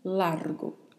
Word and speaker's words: largo: Largo, a pronúncia, atenção para o vibrato da letra R e para a letra O, que é mais fechada. Então falamos largo.